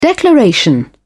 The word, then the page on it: declaration: British English